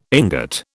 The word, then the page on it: ingot: British English